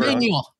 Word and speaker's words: Maignan: My only worry, actually, for AC Milan in this one is is uh, the Mike Ma- I, I, believe is I'm not sure how to mm-hmm. pronounce his name. The goalkeeper, Maignan. – Mike Maignan.